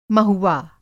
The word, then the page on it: महुवा: Hindi